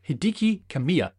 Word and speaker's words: Hideki Kamiya: Hideki Kamiya, that is how you pronounce it. I got it right that time.